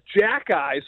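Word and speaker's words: Xhekaj: It starts with an X. It's pronounced Jack Eye.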